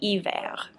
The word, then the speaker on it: hiver: And just remember that the H's in French are silent, so don't pronounce the H at the beginning of the word. So it sounds like hiver.